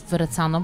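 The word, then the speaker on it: Verrazzano: Startujesz z mostu Verecano, bo już dobrze wymawiam nazwę.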